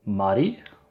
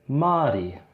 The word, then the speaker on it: Māori: Māori would be the short A. And Māori would be the longer A.